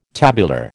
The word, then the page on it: tabular: American English